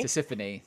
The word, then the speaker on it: Tisiphone: Tisiphone, thank you.